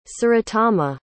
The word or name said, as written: surotama